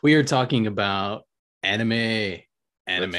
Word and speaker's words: anime: So, not to be confused with enema, which is how my art teacher in college, in animation college, pronounced it. – This town needs an enema. No. – Yep. And I couldn't figure out if she really thought it was pronounced enema or if she was actually trying to slander it.